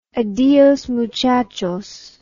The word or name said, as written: adios muchachos